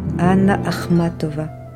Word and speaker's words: Anna Akhmatova: Anna Akhmatova. Cinq syllabes. Cinq A un double A initial, un accent tonique parfait.